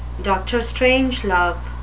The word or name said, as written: dr strangelove